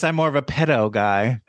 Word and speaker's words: Petto: I do think it's crazy that they call his character pedo. It's spelled P-E-T-T-O, but the way you hear it is P-E-D-O.